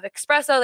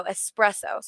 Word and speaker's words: espresso: They have espresso. They have everything that you – no, espresso. Sorry, I pronounced it wrong.